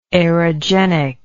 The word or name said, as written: aerogenic